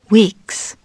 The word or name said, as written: weeks